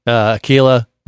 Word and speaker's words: Aquila: Aquila rhymes with tequila, gets my vote.